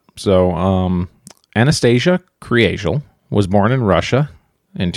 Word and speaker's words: Kriegel: So it looks like Kriegel. – Okay. – Like if you're just looking at it, K-R-I-E-G-E-L. But it's pronounced Kriegel.